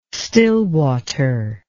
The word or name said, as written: stillwater